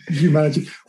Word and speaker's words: Humanergy: Well, uh, thank you, and you got the pronunciation right. – Um, I was careful about that. yeah, Humanergy.